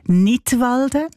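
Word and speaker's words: Knidwalden: Unter einem Titel mit einem Wortspiel Nidwalden, ausgesprochen wie der Kanton Nidwalden, aber geschrieben mit einem K vorne dran, so wie beim englischen Wort für Lismen, to knit.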